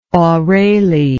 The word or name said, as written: orale